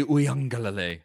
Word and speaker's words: Uiagalelei: And I think DJ Uiagalelei, I think that's the correct pronunciation.